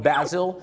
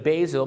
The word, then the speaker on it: basil: ซึ่งชาวฝรั่งบางคนออกเสียงว่า basil หรือ basil